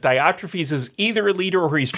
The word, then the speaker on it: Diotrephes: How'd I do on Diotrephes? Is that how you pronounce it? – That's how I pronounce it.